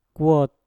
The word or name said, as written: kwot